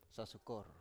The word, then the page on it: sasukor: Biak